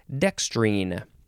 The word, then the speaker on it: dextrine: D E X T R I N, or you could put an E at the end, and that is when you would pronounce it dextrine.